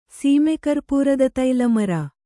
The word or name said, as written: ಸೀಮೆಕರ್ಪೂರದತೈಲಮರ